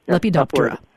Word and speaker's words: Lepidoptera: Lepidoptera.